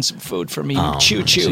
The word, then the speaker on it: Chew Chew: I'd like to talk about a product called Choo Choo, and it's spelled C-H-E-W-C-H-E-W.